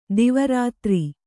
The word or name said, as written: ದಿವಾರಾತ್ರಿ